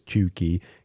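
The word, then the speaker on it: cheugy: Chuggy, pronounced as chuggy, can be used broadly to describe someone who is out of date or trying too hard.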